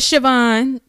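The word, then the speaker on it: Chauvin: His last name is Shavon. Shavon. It's C H A U V I N.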